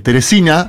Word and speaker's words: Teresina: Escuché el foro de Teresina, así se pronuncia, disculpas como lo veníamos pronunciando, el foro de Teresina de Piauí, donde también se enfocaba en esto, ¿no?